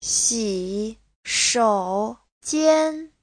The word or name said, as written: xi3shou3jian1